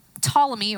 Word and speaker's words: Ptolemy: And then there's Ptolemy, or Ptolemy, if you can't pronounce silent letters, and he had a system that put the earth at the center of the universe.